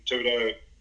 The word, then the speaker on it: Thibodeau: No, I mean, it's, and I don't know whether it, you know, I've listened to some of the stuff you had, Christian Thibodeau, that's how you pronounce it, neurotyping stuff.